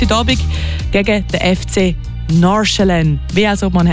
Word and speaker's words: Nordsjælland: Also eigentlich ist es gar nicht so eine Sache, aber man würde es so aussprechen. Nordsjælland. Das D sagst du eigentlich nicht und das andere ist so Sche. Nordsjælland. Ich hoffe, das hilft. – Das hilft auf jeden Fall. Nordsjælland.